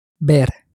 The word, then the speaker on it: ver: It's spelt V-E-R, but again, the V is pronounced somewhat close to a B. It almost sounds a bit like the word bear said in a very short way.